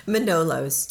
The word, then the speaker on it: Manolos: Manolos, yes.